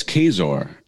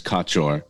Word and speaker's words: Kaczor: Oh, it's Kaczor, K-A-C-Z-O-R. Yeah, so it's Polish. Okay. In Polish, I think it's Kaczor, which literally translates to duck, like the animal, but it's Kaczor. So I guess you could say the C is silent to pronounce it.